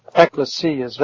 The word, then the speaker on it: Ecclesias: Ecclesias, that's the Greek pronunciation.